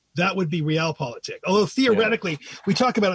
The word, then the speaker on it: Realpolitik: Okay, to another German word you discuss in your book is Realpolitik. Am I pronouncing that correctly? – That's how I pronounce it.